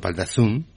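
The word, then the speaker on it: Val d'Azun: bueno, y en Val eh, mal pronunciado, como sabe usted que mi francés es... Valdazun, Valdazun. – Bueno, d'Azun, ¿no?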